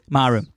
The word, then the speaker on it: Maru: Maru. – Yeah. – I'm assuming I'm pronouncing that right. It's M-A-R-U.